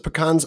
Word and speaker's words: pecans: So quick side story on pecans, because I pronounce it pecans. I was on GPB radio a couple days ago, and Scott Holcomb, the state representative we just mentioned, corrected me and said it was pecans.